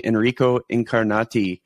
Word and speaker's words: Enrico Incarnati: And that is what brings us to bringing Enrico Incarnati onto the show.